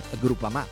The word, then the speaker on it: Groupama: Ah no, si pronuncia Groupama.